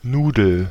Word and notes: noodle: /nuːdl/

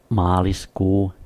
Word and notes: maaliskuu: /ˈmɑː.lisˌkuː/

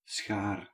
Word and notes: schaar: /sxar/

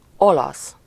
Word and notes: olasz: /ˈolɒs/

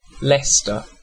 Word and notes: Leicester: /ˈlɛstə/; /ˈlɛstɚ/

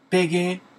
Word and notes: kleben: [ˈkleːbm̩]; [ˈkleːbən]; /ˈkleːbəm/

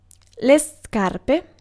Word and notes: scarpe: /ˈskar.pe/

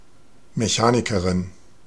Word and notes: mechanic: American English; [məˈkæn.ɪk]

